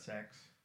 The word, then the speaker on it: CeX: They pronounce that sex.